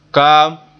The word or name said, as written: kaam